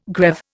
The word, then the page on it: greave: Indian